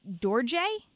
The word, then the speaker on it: Dorje: Is it Dorje? – Is it Dorje? – Dorje. Like, oh, so fancy. – Like Tarje.